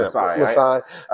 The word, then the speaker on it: Masai: Masai. – Oh, I think you said it right. Yeah. Masai.